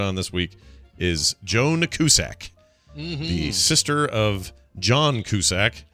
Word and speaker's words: Cusack: Like a Z instead of an S. Cusack. Cusack.